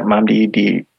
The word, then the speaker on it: Nnamdi: Mamdi? – Yeah, Mamdi Diakite. Yeah, yep. That's how you say it.